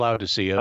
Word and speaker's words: Laodicea: Laodicea, but it doesn't really matter.